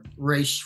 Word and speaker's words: reishi: This is the, I'm going to mispronounce it, but the reishi. – Yes, reishi.